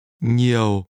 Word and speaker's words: nhiều: Nhiều. – The word that means a lot is pronounced with a low falling tone.